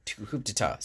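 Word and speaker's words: coup d'état: with Capitol Records releasing it in 82 as Coupe d'Etat. Coup d'etat, sorry.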